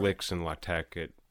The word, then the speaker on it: LaTeX: LaTeX. – I've heard it pronounced LaTeX. Yeah. – LaTeX.